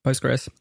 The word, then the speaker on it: Postgres: How do you say it? – Postgres. Yeah, just Postgres.